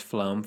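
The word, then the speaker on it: Flåm: It's pronounced Flom for those of us in the Norwegian know. – Is it? – Yeah, there's that, what's the thing called? The two dots over the A? – There's not two dots over it.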